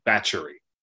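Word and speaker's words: Báthory: I, I would like to note in the Hungarian, it's pronounced batchery.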